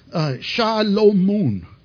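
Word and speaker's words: Shalomun (שלמה): It's actually pronounced Shalomun. Shalomun is how you would pronounce it in Hebrew.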